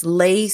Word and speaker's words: leigh: And it's lay spelled L-E-I-G-H, but pronounced L-A-Y.